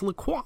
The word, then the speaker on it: La Croix: Is that how you say it? – La Croix. Yeah. – La Croix. – La Croix. No, it's La Croix. Like a croissant.